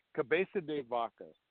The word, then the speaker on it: Cabeza de Vaca: Cabeza de Vaca.